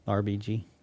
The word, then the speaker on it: RBG: So, And now I want to make sure that we understand why the passing of RGB. – RBG. – What did I say? – You said it wrong.